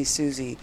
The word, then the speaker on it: Suzy: By the way, if you're going to call me Susie, spell it S-U-Z-Y with a Y, not an I-E.